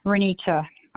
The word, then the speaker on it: Renita: Last name is Z, like zebra, I-E-S as in Sam, Z-I-E-S. – Okay, and your first? – Renita, R-E-N-I-T-A.